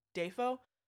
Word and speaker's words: Dafoe: So, Willem Dafoe's actual name is William, and the pronunciation of his last name is actually Dafoe. – Dafoe. – But he had a childhood friend who couldn't say William, so always called him Willem, and it caught on. And as far as his pronunciation, he was like, half of the family says Dafoe, half of it says Dafoe. I think Dafoe sounds better, so that's what I went with.